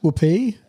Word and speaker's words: Whoopi: Is it pronounced Whoopi? – Yeah.